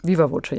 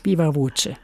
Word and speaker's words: Viva Voce: Viva Voce, ja. – Viva Voce.